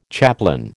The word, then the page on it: chaplain: American English